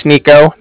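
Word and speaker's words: Niko: Okay, so yeah, like I said, my name's Nico. That's N-I-K-O, calling from the Bank of Cardiff, and that's spelled C-A-R-D-I-F-F, as in Frank Frank.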